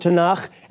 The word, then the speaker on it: Tanakh: And then we have the K sound or the Ch sound at the end of the word Tanakh, and that comes from the word Ketuvim, which refers to, beloved, the writings in the Hebrew Bible, like the Psalms.